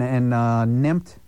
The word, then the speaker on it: NEMP: And nemped, we'll call it the verb, nemped the bus.